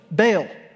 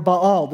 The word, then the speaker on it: Baal: This mountain also belonged to a god, one that you've heard of, Baal, or Baal, depending on how you pronounce it.